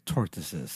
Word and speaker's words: tortoise: Okay, this is about gay tortoises. So turns out this 186-year-old tortoise has a gay lover, a 186-year-old tortoise. How do you say tortoise? – Tortoise. Like TARDIS, but tortoise.